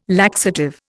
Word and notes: laxative: Indian

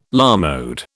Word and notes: à la mode: British English

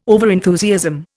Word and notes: overenthusiasm: Indian

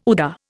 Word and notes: UDA: Indian

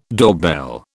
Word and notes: doorbell: British English

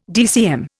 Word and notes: DCM: Indian

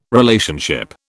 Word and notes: Relationship: British English

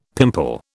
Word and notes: pimple: British English

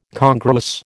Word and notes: congruous: American English

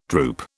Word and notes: drupe: British English

